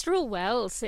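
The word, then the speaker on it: Struell Wells: And you brought us to Struel Wells. Is that how you say it? – It's Struel Wells.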